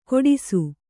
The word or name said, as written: ಕೋಡಿಸು